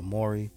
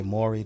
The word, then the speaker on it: Morray: So shout out to Maury. The song's called Quicksand. You spell his name. It's spelled M-O-R-R-A-Y, Maury.